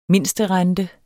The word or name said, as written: mindsterente